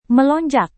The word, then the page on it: melonjak: Indonesian